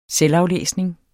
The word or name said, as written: selvaflæsning